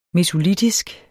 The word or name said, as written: mesolitisk